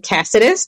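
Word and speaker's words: Cáceres: The last name is Caceres. And I butcher it myself. It's a Spanish last name, so it's actually Caceres.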